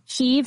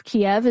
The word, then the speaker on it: Kyiv: Kyiv versus Kiev. Kiev is a Russian version of Kyiv.